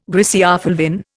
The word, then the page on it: griseofulvin: Indian